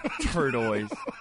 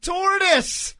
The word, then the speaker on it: tortoise: Oh my God, turtoise! How do you say it? – Tortoise.